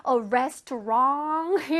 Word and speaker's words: restaurant: 근데 restaurant 이러면 안 되고요. restaurant. 이렇게 끝에 살짝 T 발음. restaurant. restaurant. 해볼래요? 시작. restaurant. restaurant. 앞에 RES 부분을 힘줘서 얘기하면 돼.